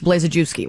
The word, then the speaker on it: Blazejewski: Chris Blazejewski, right? – Correct. Four syllables.